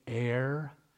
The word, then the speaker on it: aer: Do you know how you say air in Greek? Air. That's dead serious. It's spelled A-E-R, but pronounced exactly the same. Air, it means the atmosphere.